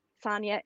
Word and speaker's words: Sonya: And the trick there is to know how to spell Sonia and Lee. So I'll spell that for you. It's Sonia, S-O-N-Y-A. Lee is L-E-I-G-H.